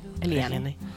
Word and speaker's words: Eliane: I always forget to pronounce an E. Eliane.